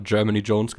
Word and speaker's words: Jermaine Jones: Da muss ich immer daran denken, wie ich damals zwei Kinder sich unterhalten habe und die den immer Germany Jones genannt haben. Das ist auch die korrekte Aussprache, bin ich mir ziemlich ja, sicher.